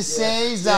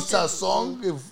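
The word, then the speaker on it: Sazón: My wife's like, the O has the thing. What do I say? Like, well, how do I pronounce that? – Oh, you mean Sazon? What the fuck is a Saison? – Yeah.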